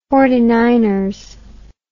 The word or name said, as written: forty-niner